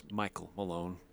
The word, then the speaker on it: Michael Malone: Quinn Snyder got out coached by Tyronn Lue and Jason Kidd and let's say it, Mike Malone back to back to back years. All in the playoffs. – It's Michael Malone. Let's make sure we say it right.